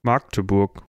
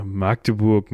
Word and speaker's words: Magdeburg: Magdeburg, Magdeburg, äh, wie spricht man das? Magdeburg. Also das, ich würde es mit T sprechen, also Magdeburg. Ich glaube, da reißt uns irgendein Magde Magdeburger, Magdeburger den Kopf ab. Ich meine mich daran zu erinnern, dass das A kurz ist, aber wir fragen einfach mal die Aussprache.